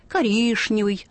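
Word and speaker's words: коричневый: И есть старшие, которые будут настаивать, коришневый, достаточно. Коришневый, конечно, уже ушел.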